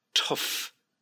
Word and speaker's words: tough: To enjoy or relish a challenge, R-E-L-I-S-H, relish a challenge. A real challenge. And then daunting or tough challenge. And that word tough, you got that oof, F sound at the end.